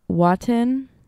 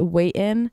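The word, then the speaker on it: Watin: Off the album called Waiten. I would pronounce it Waiten because there's no, like, that's just how I pronounce things. Waiten. It's W-A-T-I-N. To me, that's Waiten, but anyways.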